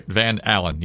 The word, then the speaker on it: Van Allen: Van Allen.